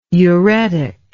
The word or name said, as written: uretic